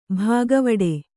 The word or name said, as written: ಭಾಗವಡೆ